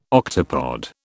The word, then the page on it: octopod: British English